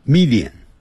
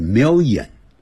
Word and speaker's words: million: We will try 就尝试 some 若干的一些 Italian，我们那个很快会念成 Italian，它发脸的音，哎，不是 Italian 这样念 Italian food，好，一条眼，好像念成一条眼，就像一百万啊，常常会听着我们老中念成 million，不是，在美式英语念成 million，没有眼睛 million， Italian。